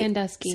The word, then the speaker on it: Sandusky: Uh, I always pronounce his name wrong. Sandensky, Sandensky, Sandusky, oh, Sandusky. Sandusky.